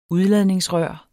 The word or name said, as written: udladningsrør